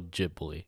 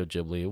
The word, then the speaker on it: Ghibli: Because I know it's spelled kind of like the Italian Ghibli, which would mean it would be pronounced like Ghibli. But apparently in Japanese, when it's pronounced, they say Ghibli.